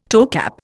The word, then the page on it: toecap: Indian